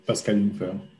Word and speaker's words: Pascal Jungfer: And you pronounce it well, Pascal Jungfer.